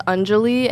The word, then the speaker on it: Anjali: It's Anjali, and it's spelled A-N-J-A-L-I, but I never went by that.